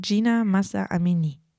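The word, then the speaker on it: Jina Mahsa Amini: Habe ich das jetzt richtig ausgesprochen? Gina Massa Amini. Richtig.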